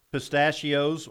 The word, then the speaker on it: pistachio: What how do you spell it again? – P-I-S-T-A-C-H-I-O. – Okay. – Pistachio. – So it's kind of like Pinocchio except different.